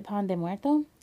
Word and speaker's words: pan de muerto: is it pan de muerto? – Yes, that it's specific bread for Day of the Dead.